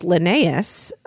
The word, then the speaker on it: Linnaeus: In the Western world, Linnaeus described the species in 1753. – Okay. – Did I pronounce his name right? – You did.